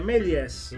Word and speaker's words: Méliès: anzi sicuramente certo, che sto imparando a pronunciare nel tempo, che è Méliès, giusto? – Ba-bam, giusto. Ba-ba-bam.